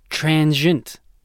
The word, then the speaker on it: transient: It has two syllables, not three, so it's transient. – Transient. – Not transient. Transient.